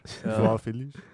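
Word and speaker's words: João Félix: João Felix.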